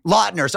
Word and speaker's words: Lautner: Taylor Lautner, who was in, did I say that wrong? The Quaducer, what's his? – Lautner.